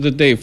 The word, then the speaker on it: Τέτε: Μπορεί όντως αυτό ο γιος του Μίχου μας είχε πει, γιατί τον ήξερε πολύ καλά, ότι προφέρεται Τέτε. – Άλλη σχετικά με τον Τέτε, όντως στα χαρτιά το επίθετο ήταν Τέτε. Αλλά πάντα από παιδάκι μέχρι και σήμερα όλοι τον φωνάζουν Τετέ.